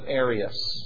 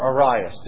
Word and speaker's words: Arius: in the teachings of Arius, okay, or Arius, depending how you wish to pronounce it.